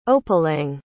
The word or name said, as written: opelenge